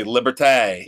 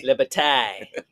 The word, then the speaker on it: Liberté: But yeah, it's L-I-B-E-R-T-E with a little accent on it. So how would you say that? I would say Liberté. – Liberté.